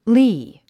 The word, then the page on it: lea: líː; リー